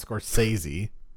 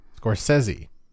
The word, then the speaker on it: Scorsese: We are reviewing Killers of a Flower Moon from one of our favorite directors, Marty Scorsese, which I've now found out is how you say it. Not Scorsese, Scorsese.